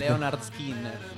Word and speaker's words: Lynyrd Skynyrd: Leonard Skinner.